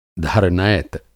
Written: धरनैत

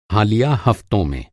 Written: हालिया

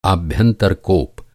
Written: आभ्यंतर

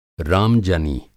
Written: राम-जनी

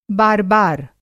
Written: बार-बार

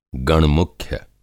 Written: गणमुख्य

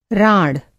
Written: राँड़

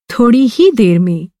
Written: ही

